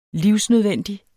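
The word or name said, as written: livsnødvendig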